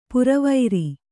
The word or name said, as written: ಪೊರವಾರ್